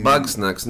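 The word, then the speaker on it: Bugsnax: Περνάμε στο Back Sacks. Αν το προφέρω σωστά, Back Sacks λεγότανε. Ε, ένα παιδικό ήταν εκεί πέρα με mm. κάτι. Bug snacks, ναι.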